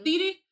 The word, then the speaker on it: Thede: And Robin Thede, did I say that right? Thede? – Thede.